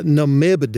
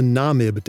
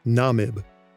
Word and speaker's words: Namib: It's not the Namib Desert, it's the Namib Desert, Namib. And it's the Thar or Tur Desert, not Thar. Thar is spelled T-H-A-R, but it's pronounced with just a regular T sound.